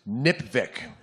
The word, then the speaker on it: NPVIC: NPVIC can be pronounced NIPVIC. It rhymes with lipstick, it is easy to remember, and if we just spread NIPVIC across the country, we can make the Electoral College so beautiful.